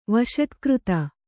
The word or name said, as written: ವಷಟ್ಕøತ